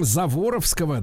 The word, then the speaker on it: Заворовского: значит, обладает ученой степенью, кандидат наук, является автором знаменитого м, Заворовского, да, я правильно ударение делаю? Правильно. Да, Заворовского метода выращивания семенного картофеля.